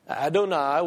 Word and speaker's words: Adonai: We have Adonai. Adonai, which rhymes with El Shaddai.